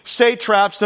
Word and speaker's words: satrap: He sets up 120 satraps to be over the whole kingdom. What are satraps? And by the way, I looked up how to say it. I'm saying it correctly, just in case. Satrap.